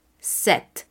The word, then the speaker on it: sept: En el número 6, esta X que se queda al final porque se escribe S-I-X esta X al final se convierte en el sonido de una S prácticamente, cis. En el número 7, que es S, E, P, T, no diremos la P aunque la escribiremos, no la vamos a pronunciar. Entonces el sonido es set.